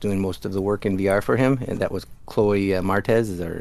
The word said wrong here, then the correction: Moretz: Was that her name? Am I saying that right? – Moretz, yeah.